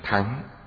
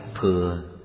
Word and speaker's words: Thừa: Chữ này âm cổ đọc là Thắng. Bây giờ mọi người đều đọc Thừa, bây giờ đọc Thắng không ai hiểu. Chúng ta tùy tục mà dùng là Đại Thừa.